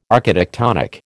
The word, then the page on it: architectonic: American English